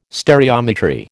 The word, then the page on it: stereometry: American English